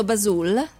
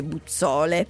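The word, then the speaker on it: Buzzoole: Allora, torna a Radio Tube che è responsabile marketing di Basul. Ciao – Ciao, tanto l'ho pronunciato giusto, Basul? – Basul è giusto, perfetto. – Mi piace, perché si scrive Buzzole, però non si legge Buzzole.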